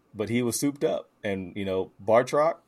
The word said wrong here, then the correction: Batroc: was that how you pronounce his name? Like Bartrock? It's Batroc.